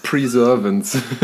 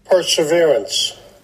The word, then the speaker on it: Perseverance: häufigsten Alternativen war Perseverance und Preservance, die ich gehört habe. Aber für alle, die es uns nicht glauben, dass wir es richtig aussprechen, hier kommt einmal so klingt es.